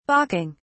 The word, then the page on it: balking: British English